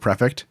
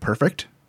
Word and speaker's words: Prefect: For the longest time, for some reason, my brain did not read Prefect. It read Perfect. Even though it's spelled Prefect and it's the name of a car, I would always call him Ford Perfect.